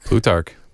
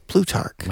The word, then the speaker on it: Plutarch: There's a collection of proverbs attributed to Plutarch. – Plutarch. – Plutarch. – I believe is how it's said, yeah.